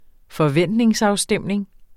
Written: forventningsafstemning

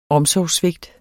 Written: omsorgssvigt

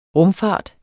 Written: rumfart

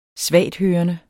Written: svagthørende

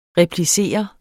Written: replicere

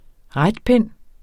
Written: retpind

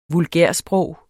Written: vulgærsprog

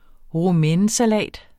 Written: romainesalat

